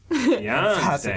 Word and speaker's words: Tianste: The emphasis. – Tianste. – it's like Beyonce, but with a T.